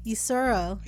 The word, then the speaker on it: Usoro: Usoro.